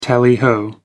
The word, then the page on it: tally ho: American English